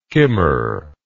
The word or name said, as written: kimmer